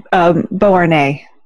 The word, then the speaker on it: Beauharnais: If you give me the spelling, I should be able to pronounce it for you. – B-E-A-U. – Mm-hmm. – Harnais. – Oh, I'd say Beauharnais. – Beauharnais. – Beauharnais. – Sounds right.